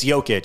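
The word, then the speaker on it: Jokić: I knew it was an itch, but it's Jokic.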